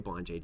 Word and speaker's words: Bonjay: So it's not quite Bonjay. It's more like Bonjay.